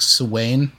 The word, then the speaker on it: Samhain: I believe in Gaelic it's pronounced like Samhain.